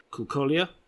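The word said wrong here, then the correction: Cucciolla: Cucciola. – Sorry, oh, it's a double C. Sorry, Cucciola. Which he had a very long and sort of stable career, but he did a lot of dubbing work for Anglophonic films.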